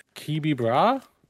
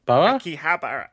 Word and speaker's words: Akihabara: Supernatural creatures are stalking the pop culture mecca of Kibibra? – Akihabara.